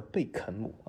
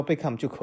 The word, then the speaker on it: Beckham: 但是其实他不叫贝克汉姆，他叫贝肯姆啊。这其实也是一个，就是我们讲这个读音的一个重要的点，就是什么？很多人讲啊，那既然能沟通，那你就叫贝克汉姆就可以了嘛。